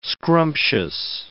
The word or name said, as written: scrumptious